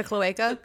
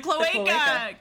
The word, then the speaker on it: cloaca: I want to call it the cloche, and that's not it. – The cloaca? – The cloaca, because I think that is relevant.